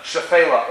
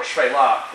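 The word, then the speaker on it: Shephelah: and he mentioned this idea of the Shefela, or Shvela, depending on how you want to pronounce it, ask a Bible teacher.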